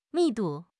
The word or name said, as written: 密度